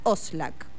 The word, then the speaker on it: Oszlak: esta vez les traigo a Oscar Oslak. Oslak se escribe O-S-Z-L-A-K, ¿sí? Oscar Oslak.